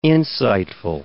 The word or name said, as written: insightful